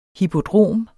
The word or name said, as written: hippodrom